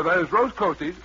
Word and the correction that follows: Post Toasties: Hey, get the name right, my confused friend. It's Post Toasties.